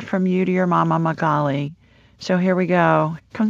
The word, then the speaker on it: Magali: Her name is Magali. – Magali, spell for me. – Yeah. Spell it is M-A-G-A-L-I. – Magali, just like it sounds.